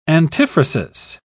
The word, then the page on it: antiphrasis: British English